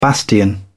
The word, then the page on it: bastion: British English